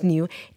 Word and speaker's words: NGEU: It's spelled N-G-E-U. – Yes. – Okay, because everybody's going to start searching for new. And it's pronounced new, N-G-E-U Solutions.